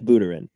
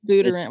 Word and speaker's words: Buterin: Yeah, I think Vitalik Buterin, but Buterin. It, well, all right. – His name deserves to be butchered. – Wrong emphasis on the wrong syllable.